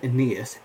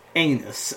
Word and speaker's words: Aeneas: And Mr. Weston, at the start of the year, always goes, okay, so we're going to written the Aeneid with Aeneas, and it's called Aeneas, and it's not pronounced anus, okay?